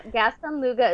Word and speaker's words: Gaston Luga: I hope I said that right, Gaston Lucia. – Gaston Luga is proud of its Swedish design, vegan products, free delivery, and returns worldwide.